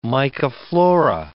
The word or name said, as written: mycoflora